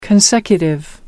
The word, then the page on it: consecutive: American English